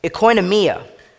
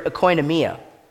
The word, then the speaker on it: oikonomia: That word dispensation in the Greek is called oikonomia. And you can really hear our word economy in when I pronounce the Greek word oikonomia.